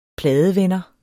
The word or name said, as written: pladevender